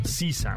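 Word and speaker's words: SZA: Se pronuncia Sisa, amigos.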